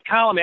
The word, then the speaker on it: Colom: Well, it's Colom. I had to look it up on Baseball Reference. They have a little pronunciation guide there, which I point Gene Honda to every once in a while during the regular season.